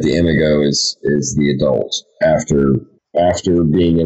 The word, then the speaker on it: imago: Is that how you say it, in imago? – Yeah, imago. It's imago.